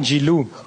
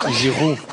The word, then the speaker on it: Giroud: Not with the L. Not Giroud. Giroud. – Oh! Olivier Giroud! – No! No W. Not Giroud.